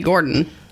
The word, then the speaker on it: Gorton: I don't think he pronounces his name Gorton, but that's how it's spelled. – Oh, whatever. – Why isn't it with a D? It's G-O-R-T-O-N.